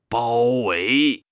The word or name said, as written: 包围